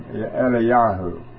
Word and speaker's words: Eliyahu: Elijah, when you pronounce it in the Hebrew, it's two parts of the Father's name, Eliyahu.